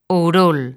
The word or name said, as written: Ourol